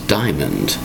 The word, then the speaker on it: diamond: Today's word is diamond. Diamond. Two distinct syllables with D-I-A-M-O-N-D.